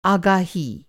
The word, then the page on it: आगही: آگہی